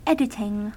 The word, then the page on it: editing: American English